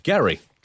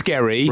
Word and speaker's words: Gerry: Well, I think it's pronounced Gerry. Isn't it? – It was Gerry, right. But because people are thick, right. – It changed.